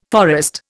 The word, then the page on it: forest: Indian